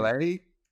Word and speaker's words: Milei: Oh God, you're gonna kill Milei.